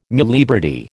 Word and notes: muliebrity: American English

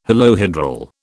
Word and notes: holohedral: British English

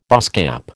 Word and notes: baasskap: American English

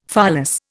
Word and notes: pholas: Indian

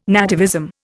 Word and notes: nativism: Indian

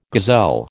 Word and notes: gazelle: American English

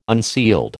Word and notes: unsealed: American English

